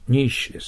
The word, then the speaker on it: niche: ニッシュです。イギリスの発音で。